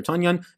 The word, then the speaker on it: Tonyan: A couple people tell me now that it's pronounced Tonyan, Tonyan. It's been pronounced for 2 years.